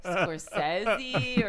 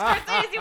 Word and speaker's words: Scorsese: No, it was because you kept saying, how do you pronounce Scorsese? And everybody was like, it's Scorsese. And you were like, are you sure it's not like Scorsese? Or and everybody was like, no, it's fucking Martin Scorsese.